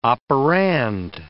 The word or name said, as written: operand